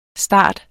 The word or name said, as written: starter